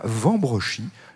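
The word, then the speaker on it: Wambrechies: Des choses magnifiques dans ce musée de la poupée et du jouet ancien, dans ce château de Robersart de Vambrochy, dont le nom s'écrit avec un W au début, mais on dit bien Vambrochy. J'ai vérifié, croyez-le bien.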